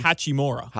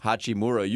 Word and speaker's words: Hachimura: How do you pronounce the forward out of Gonzaga? Number 11, Rui Hachimura. – Hachimura.